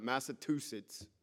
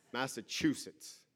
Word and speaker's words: Massachusetts: Not Massachusetts, Massachusetts. Learn how to say the word.